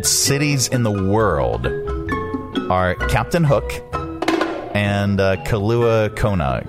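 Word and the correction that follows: Kailua-Kona: Kailua Kona.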